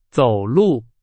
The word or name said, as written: 走路